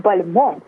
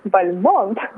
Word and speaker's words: Бальмонт: Неужели это Константин Дмитриевич Бальмонт родился? – Бальмонт. – Прошу прощения. – Бальмонт, да. Обратите внимание на ударение, да, на последний слог.